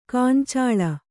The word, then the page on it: ಗೊಂಚಲ್: goncal